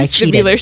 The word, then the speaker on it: vestibular: The vestibular system.